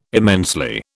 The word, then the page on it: immensely: British English